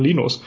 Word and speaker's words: Linus: Genau, kennst du äh, Leines äh, Torvalds? Äh, ja, ich glaube, der spricht man Linus.